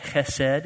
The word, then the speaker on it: Chesed: Chesed is how that's pronounced.